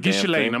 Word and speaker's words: Ghislaine: Ghislaine, I'm going to go Lane.